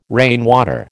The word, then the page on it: rainwater: American English